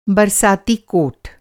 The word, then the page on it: बरसाती: برساتی; Hindi